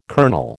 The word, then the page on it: colonel: American English